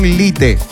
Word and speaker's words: Lite: Ah, ya, hay que descargar la versión Lite. O sea, así como lo estoy escuchando, así como lo estoy pronunciando.